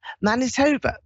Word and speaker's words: Manitoba: Manitoba, that's it, that's how you pronounce it.